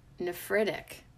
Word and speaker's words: nephritic: Today, we are going to get na-na-na-na-na-nerdy with the word nephritic. Hold up. Did I say it right? – Nephritic. – Nephritic. N-E-P-H-R-I-T-I-C.